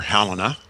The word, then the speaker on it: Helena: Yeah, something happened when Helena, or Helena, depending on the way you choose to pronounce it, started to build edifices throughout the Holy Roman Empire, starting in Israel.